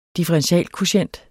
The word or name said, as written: differentialkvotient